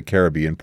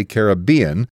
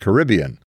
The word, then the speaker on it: Caribbean: Trivial note, you notice I say Caribbean as against Caribbean, because experience has convinced me that Caribbean is the right way to say it.